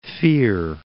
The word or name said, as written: Fear, Cape